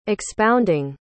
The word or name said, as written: expounding